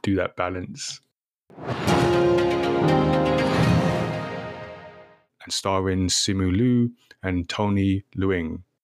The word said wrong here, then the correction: Leung: I said that wrong. – Long.